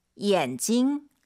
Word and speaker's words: 眼睛: 眼睛. – 안경은요? – 眼镜. – 대체 뭐가 달라요? – 发音不一样. – 네, 발음이 사실 다르대요. – 声调. – 성조. 네. 안경은 찡이 사성이에요. 眼镜. 눈은 삼성 경성이에요.